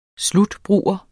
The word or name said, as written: slutbruger